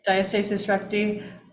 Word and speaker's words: diastasis recti: And so you pronounce it so beautifully, diastasis recti.